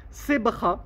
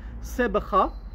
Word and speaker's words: sebkha: Sebra, cherchez dans le dictionnaire, S-E-B-K-H-A, euh, je crois que ça s'écrit comme ça en français. Sebra, ça vient de l'arabe sabra.